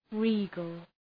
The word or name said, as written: regal